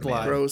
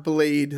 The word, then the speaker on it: Blythe: Blythe. Gross man. Yeah. It's not Blade. Like, that's right. Like it's spelled it's Blythe.